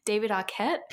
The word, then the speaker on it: Arquette: This movie starred 90s icons Neve Campbell, Courtney Cox, I'm going to say it wrong, David Arquette.